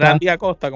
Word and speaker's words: Randy Acosta: Ronde Acosta. – Randy Acosta, como le dicen.